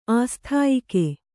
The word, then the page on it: ಆಸ್ಥಾಯಿಕೆ: āsthāyike